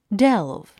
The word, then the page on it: delve: délv; デェゥブ